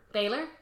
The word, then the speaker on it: Baylor: It's Taylor, but B instead of a T. – Baylor? – Rudy Baylor? – I would imagine so. – Rudy Baylor, a young attorney with no clients, goes to work for a seedy ambulance chaser.